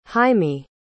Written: haimi

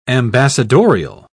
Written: ambassadorial

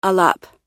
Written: alap

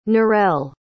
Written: norelle

